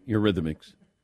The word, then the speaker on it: Eurythmics: Yeah, Eurythmics. – Okay. – Yeah. Okay. – Are they the Derrick Rose of the Rock and Roll Hall of Fame? – I mean, Annie Lennox, Eurythmics.